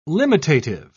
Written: limitative

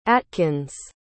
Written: atkins

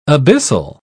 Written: abyssal

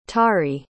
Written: tari

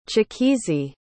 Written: chikezie

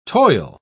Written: toil

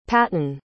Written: patton

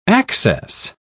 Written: access

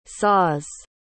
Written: saws

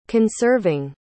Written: conserving

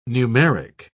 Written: numeric

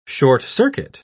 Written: short-circuit

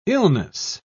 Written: illness

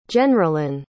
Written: jenralyn

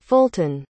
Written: fulton

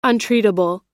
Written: untreatable

